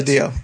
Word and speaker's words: DiDio: But then, and I'm sorry, how do you say his name? DiDio? – Yes. DiDio. Dan DiDio.